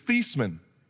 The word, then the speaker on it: Theismann: Fun fact, Joe Theismann's real last name, the way you're supposed to pronounce it, is Theismann. But the PR guy at Notre Dame said it would be better if he were to change the way he said his last name so it would rhyme with the Heisman Trophy.